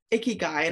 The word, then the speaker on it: ikigai: Ikigai, and it's spelled I-K-I-G-A-I.